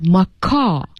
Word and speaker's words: macaw: So you really should pay attention that you don't pronounce the W here, macaw.